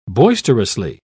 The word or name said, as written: boisterously